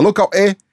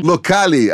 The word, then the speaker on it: Locale: Então, a gente pronuncia locale. Locale? Ah, então melhor, bacana. Então é locale.